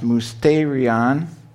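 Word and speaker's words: musterion (μυστήριον): But looking up that word in Strong's dictionary, it's pronounced musterion, means a mystery, a secret, of which initiation is necessary.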